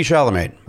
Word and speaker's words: Chalamet: Uh, Pete Davidson and Timothy Chalamet.